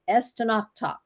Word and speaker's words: Estonactoc: I have a lot of people that have a hard time saying my last name, but it's pronounced Estonactoc.